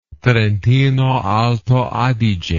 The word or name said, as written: Trentino-Alto Adige